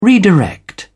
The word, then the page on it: redirect: British English